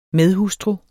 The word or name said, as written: medhustru